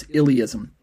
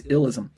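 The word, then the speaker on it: illeism: That's illyism. I am embarrassed to say that I kept mispronouncing it as illism.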